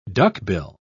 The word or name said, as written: duckbill